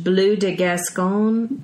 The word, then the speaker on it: Bleu de Gascogne: French Bleu de Gascogne. – I think that's right on.